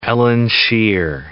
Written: Eilean Siar

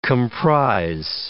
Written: comprise

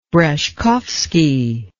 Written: breshkovsky

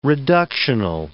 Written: reductional

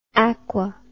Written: aqui

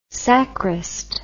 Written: sacrist

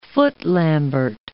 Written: footlambert

